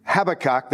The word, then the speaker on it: Habakkuk: Habakkuk, that's how it's pronounced properly by people who learn English.